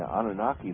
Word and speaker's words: Anunnaki: And it was suggested that not only did beings that one you referred to as the how do you pronounce that, the Anunnaki? – Anunnaki. – The Anunnaki. I'm not saying that properly, they corroborate that, but they also go on to suggest that there were many others.